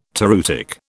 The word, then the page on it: toreutic: British English